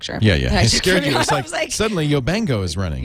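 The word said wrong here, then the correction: Yobongo: Is that not how you say it? Yo bango? – Yo bongo. Like bongo drums.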